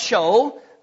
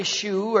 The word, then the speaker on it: shew: It's a word, if you will, it's the Old English spelling of the word, S-H-E-W. It's pronounced show. You can say shoe or whatever you want to say, but it depends on what phonetic ending you want to put on it.